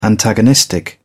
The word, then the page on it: antagonistic: British English